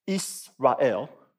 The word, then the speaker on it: Israel: This is the word Israel in Hebrew. It's pronounced Israel.